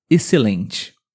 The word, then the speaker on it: excelente: Antes da letra C de casa, também o X tem som de S. Por exemplo, excelente.